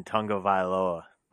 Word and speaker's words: Tagovailoa: I believe you pronounce his last name Tagovailoa. – Nailed it.